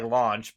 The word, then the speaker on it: Lunch: So they actually always pronounce it like Launch, but it's spelled Lunch in the actual manga.